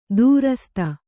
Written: ದುರಾಸದ

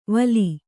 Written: ವಲಿ

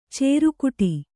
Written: ಚೌರಿಕೊಟ್ಟು

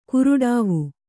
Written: ಕಾರಡವಿ

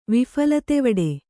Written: ವಿಫಲತೆವಡೆ

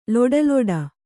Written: ಲೊಡಲೊಡ

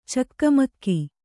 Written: ಚಕಮಕ

